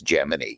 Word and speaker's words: Gemini: with establishing the pronunciation as Gemini.